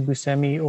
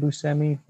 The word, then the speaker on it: Buscemi: And speaking, of course, of Steve Buscemi or Buscemi, however you want to pronounce it, he says Buscemi because his dad said Buscemi, but the real actual pronunciation is Buscemi.